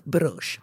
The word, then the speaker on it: Bruges: Att man väljer själv och det uttalas Bruges.